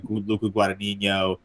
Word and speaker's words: Luca Guadagnino: And I know this is based off of a book, so it's not like Luca Guadagnino was just a psychopath who just thought of a cannibal.